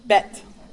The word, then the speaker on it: Bet: But the next one might throw you off because it is not pronounced like Mrs. Jones' name. Bet. Yep. So you're going to ignore that H there.